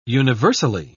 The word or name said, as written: universally